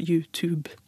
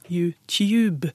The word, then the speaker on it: YouTube: Og det konkrete forslaget til svar her er at begge deler er rett. Men denne unge har en amerikansk-engelsk uttale av YouTube. Oh. Mens den voksne har en britisk-engelsk uttale, YouTube.